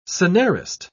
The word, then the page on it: scenarist: British English